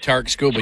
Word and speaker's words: Skubal: can't remember how you say his name, the Scooble. – Guy from Detroit. – Tarik Skubal, yeah.